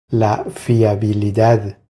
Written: La fiabilidad

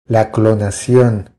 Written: La clonacion

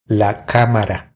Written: La camara

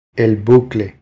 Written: El bucle

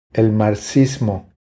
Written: El marxismo

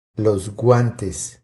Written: Los guantes

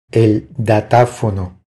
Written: El datafono

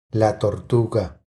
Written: La tortuga

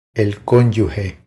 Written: El conyuge

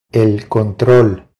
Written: El control

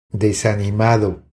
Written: Desanimado

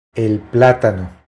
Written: El platano

